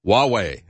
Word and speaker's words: Huawei: Nevertheless, Huawei, I think is the way you pronounce that, H-U-A-W-E-I.